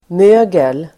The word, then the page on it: mögel: [m'ö:gel]